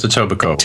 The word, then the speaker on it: Etobicoke: It's pronounced Etobicoke.